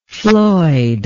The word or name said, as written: floyd